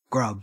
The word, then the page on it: grub: Australian English